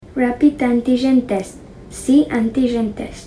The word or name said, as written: rapid antigen test